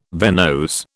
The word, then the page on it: venose: British English